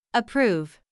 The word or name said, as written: approve